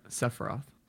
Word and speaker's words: Sephiroth: How do you say that? – Yes, Sephiroth.